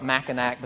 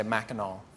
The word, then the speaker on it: Mackinac: spelled like Mackinac, but the locals say Mackinac.